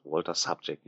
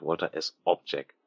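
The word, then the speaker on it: Walter Sobchak: Ja, und nochmal hier, es heißt Walter Subject, nicht Walter S. Object.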